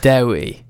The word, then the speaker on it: Dewi: Dewi.